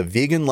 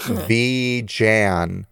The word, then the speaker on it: vegan: 3 months after coining the term, he issued a formal explanation of the way the word should be pronounced. Vegan, not V-jan, he wrote.